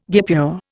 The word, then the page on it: guipure: Indian